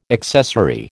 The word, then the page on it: accessory: American English